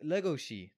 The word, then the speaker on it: Legoshi: Legoshi. Legoshi. – Yeah. – Legoshi. – I just said it wrong. My bad. – Yeah, you gotta have that right accent on it.